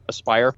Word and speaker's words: Aspyr: Aspyr, right? That's how you pronounce that, right? Not yes. – Asper, but Aspyr,